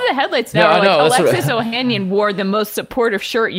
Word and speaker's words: Ohanian: Alexis Ohanian, is that how you say his name? – Yeah.